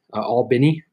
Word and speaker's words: Albany: Albany, gotta say it right for my Georgians out there.